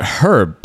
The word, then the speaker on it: herb: we pronounce it Herb because it has a fucking H in it.